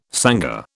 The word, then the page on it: sangar: British English